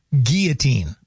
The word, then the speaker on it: guillotine: And then I still have to find out the correct pronunciation of guillotine. – Guillotine. – Is that what it is? – That'd be the French. Guillotine.